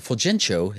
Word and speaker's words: Fulgencio: So Fulgencio, or Fulgencio, how they pronounce it in the... – Fulgencio. – Fulgencio is the Italian pronunciation.